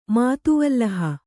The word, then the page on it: ಮಾತುವಲ್ಲಹ: kannada